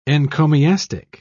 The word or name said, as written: encomiastic